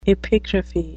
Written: epigraphy

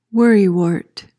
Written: worrywart

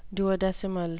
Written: duodecimal

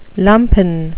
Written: lumpen

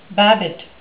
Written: babbitt

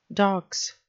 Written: dox

